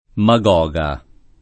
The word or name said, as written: Magoga